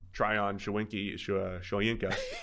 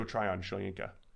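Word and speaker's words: Tryon-Shoyinka: Tryon Shoyinka. And I mispronounced his name. – Tryon Shoyinka.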